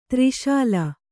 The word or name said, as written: ತೆರೆಸೇಲೆ